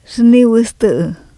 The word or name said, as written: zhniwU7tU7